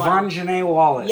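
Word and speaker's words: Quvenzhané Wallis: Quavon Janae Wallace.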